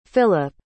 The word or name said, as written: Phillip